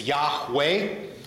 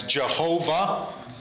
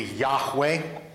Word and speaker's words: Yahweh: And so the pronunciation of it, to my understanding, most Hebrew scholars will assert that the pronunciation is Yahweh, but there are others that put another vowel in between the H and the W to where it's three syllables, and that's where you get like the English King James translation as Jehovah.